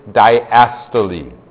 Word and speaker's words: diastole: They're called systole, and the second one is diastole. That's how it's pronounced.